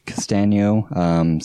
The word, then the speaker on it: Castagno: And then finally, yeah, you can just find me on Facebook, Twitter, Instagram at Vote Castanio. So my last name is spelled out Castagno.